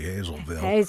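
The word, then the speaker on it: Hazlettville: Yeah, we have something that's spelled Hazletville, and it's actually Hazelville. – That's how it's pronounced.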